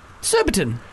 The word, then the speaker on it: Surbiton: We got Phoebe from Surbiton.